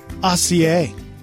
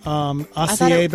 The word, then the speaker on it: acai: Oh, somebody on the live line said, what about Aussie? I used to mispronounce that all the time. – What is that? Like Aussie hairspray? – It's that berry that you get. Acai berry? – I think it's acai.